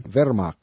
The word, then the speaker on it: Wehrmacht: It's not Wehrmacht? – No, it's Wehrmacht. – God, I've been saying it wrong for years. – It's pronounced V. Wehrmacht.